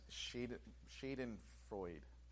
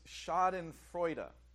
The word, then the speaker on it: Schadenfreude: This word, and for a long time I pronounced it as Schadenfreude. Apparently that was not the proper pronunciation. It's Schadenfreude. As I said, it's a German word, Schadenfreude.